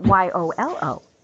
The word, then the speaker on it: YOLO: And am I saying, or do you want it pronounced as in Y O L O? No, YOLO is fine.